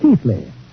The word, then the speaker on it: Keighley: The Brontes lived in Yorkshire, England, in the 19th century, not far from my ancestral town of Keighley, pronounced in England Keithley, although how they made Keithley out of K-E-I-G-H-L-E-Y, I'll never know.